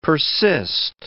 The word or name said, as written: persist